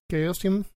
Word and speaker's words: Chaosium: En español se lee Chao Xiong, pero supongo que se lee que como Chaos, Chaosium. – ¿Cómo se llama la compañía? – Chao Xiong se escribe. – Ah, ok, ya veo. – Sería Chaosium, como sea.